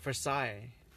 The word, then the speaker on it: Versailles: Here, how about this town called Marseille or Versailles? – Versailles. – You know, there's a lot of letters in the end that are not pronounced, that like the L-L-E-S.